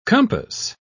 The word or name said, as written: compass